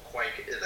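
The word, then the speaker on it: kveik: But what you're specifically looking for is these strains that are called Quaik. That's K-V-E-I-K. That's why nobody knows how to say it.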